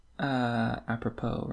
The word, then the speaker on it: apropos: Apropos, right? However you say that word.